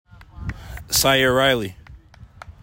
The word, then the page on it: Syaire Riley: SIGH air RYE lee